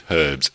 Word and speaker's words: herbs: It's herbs. It's spelled H-E-R-B-S, not E-R-B-S.